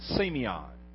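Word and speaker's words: semion (σημεῖον): The word sign in the Greek language is a word that we pronounce semion, almost like Simeon. Semion.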